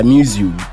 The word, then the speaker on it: Mewzu: Is that how you pronounce it, though? – Yeah, Muzu. – Muzu. – Muzu, yeah. I discovered this song last week, yo. – Muzu. – Muzu. – Muzu. – Oh, this is like, did you watch Pokemon? – Yes. – You know, Mewtwo. Yeah, that's how you say it.